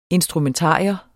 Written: instrumentarium

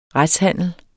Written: retshandel

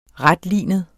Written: retlinet

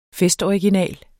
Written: festoriginal

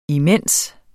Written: imens

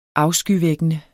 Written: afskyvækkende

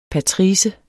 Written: patrice

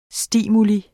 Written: STIMULIENE

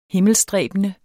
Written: himmelstræbende

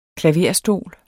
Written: klaverstol

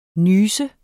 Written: nyse

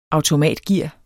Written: automatgear

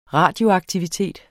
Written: RADIOAKTIVITET

